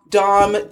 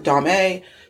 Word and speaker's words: Domme: I'm like, no, Dom, D-O-M-M-E, and D-O-M are pronounced Dom. If there's not an accent ague at the end of it, it's like not Dame.